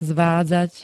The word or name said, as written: zvádzať